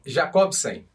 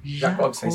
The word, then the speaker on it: Jacobsen: É que em, em algumas línguas o J pronuncia como um som de I. Então algumas pessoas chamam, na Alemanha, por exemplo, ia chamar de Jacobsen, né? – É verdade, é verdade, sim.